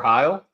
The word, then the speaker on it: Heil: They can find me at FF Tyler Heil, and that's H-E-I-L.